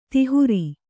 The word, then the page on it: ದಾಹೀರೆ: dāhīre